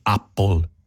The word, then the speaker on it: Apple: Comunque, Apple si pronuncia con la A aperta, non E, quindi Apple.